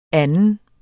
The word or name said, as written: anden